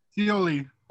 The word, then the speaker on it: Scioli: This is by Tom Scully. Somebody pronounce that speaks Scioli. – Okay, there we go. Scioli.